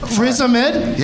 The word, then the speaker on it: Riz Ahmed: Riz Ahmed? – Riz Ahmed? – Yeah.